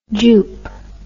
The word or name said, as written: jupe